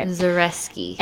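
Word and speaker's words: Zakrzewski: Zach Reski, you don't pronounce the second Z. – Wait, you don't pronounce the second Z? – The Zach Reski. – Reski. Okay. – Zareski.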